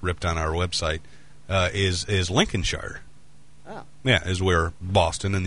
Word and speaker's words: Lincolnshire: I mean, Lincolnshire.